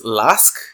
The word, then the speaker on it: LASK: Spurs versus LASK.